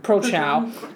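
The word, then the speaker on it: Prochnow: Prochow.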